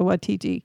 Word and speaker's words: Waititi: Stu Rutherford is actually a college friend of Taika Waititi. And hey, you said it right.